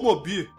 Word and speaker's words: Moebius: principalmente com artistas porra loucas como Moebius, ou Mobi. – É, Mobi é a pronúncia correta do saudoso Moebius aqui no Brasil.